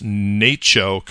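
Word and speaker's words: NACCHO: I went with my friends from Public Health down to their annual, now they pronounce this NACHO conference, the National Association of City and County Health Officials.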